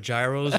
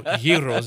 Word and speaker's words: gyros: It seems like you've been eating a lot of gyros, or gyros is a proper way to say it, but perfect.